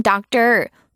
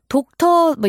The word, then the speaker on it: doctor: 요거 여러분 doctor, 독터 뭐 이렇게 하시는 분도 없겠죠? doctor 이렇게 발음됩니다.